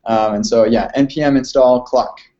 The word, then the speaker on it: cloc: First is I just released a new Node module called Clock. But it's actually just a wrapper around something that somebody else wrote, Al Daniel, and it's spelled C-L-O-C, and it stands for Count Lines of Code or something like that.